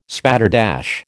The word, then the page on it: spatterdash: American English